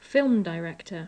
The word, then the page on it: film director: English